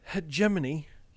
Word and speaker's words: hegemony: And the signal was one that had been used by the European hegemony in the fictional 22nd century. – Do you pronounce it hegemony or hegemony? – Hegemony.